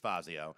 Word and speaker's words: Fazio: I pronounced it both ways there, but it's Fazio.